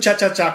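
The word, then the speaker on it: chá-chá-chá: E o tchá-tchá-tchá, como é que fala tchá-tchá-tchá? É assim mesmo, né? Tchá-tchá-tchá. Assim mesmo, assim mesmo.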